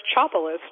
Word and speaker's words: Chopelas: Well, my last name is pronounced Chopalis, just like it's spelled.